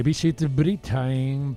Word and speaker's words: Visit Britain: Bienvenida aquí in situ a Relaciones Públicas de Visit Britain para España. ¿Cómo estás? – Muchas gracias. – Lo he dicho bien, Visit Britain, perfecto.